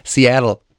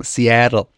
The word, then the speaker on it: Seattle: Obacht beim Seattle aussprechen. – Ob der korrekten Aussprache. – Richtig. Seattle. Jetzt nochmal alles zusammen. Seattle.